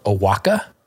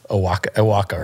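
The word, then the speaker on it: Oaxaca: And it's called Owaka, but it's spelled O-A-X. – Yeah. – And so the first time I walked in there, I was like, Owaxeta? – Well, that's embarrassing. – Well, I'm like, I'm sorry. How do you pronounce that? And the woman told me. I'm like, okay, now I know. Owaka, right? – Yeah.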